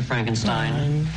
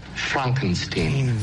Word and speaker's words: Frankenstein: I have one question, Dr. Frankenstein. – No Frankenstein. – That's Frankenstein. I beg your pardon? My name, it's pronounced Frankenstein.